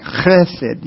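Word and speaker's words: chesed (חסד): And if you want to say it right, it's actually kind of like chesed. You'd have to the beginning of it. Because it's like a H, but with a rough guttural sound.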